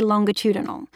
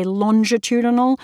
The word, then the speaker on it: longitudinal: Another negotiation that I undertook was maintaining the right to say longitudinal, which apparently Americans say longitudinal, but I declined to do that.